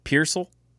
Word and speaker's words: Pearsall: Purcell? – Ricky Purcell. – Purcell, okay. – P-E-A-R-S-A-L-L. – Pearsall? – Pearsall.